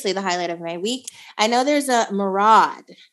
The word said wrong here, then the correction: myriad: Myriad. – Thank you. I knew I said that wrong, so I paused for correction.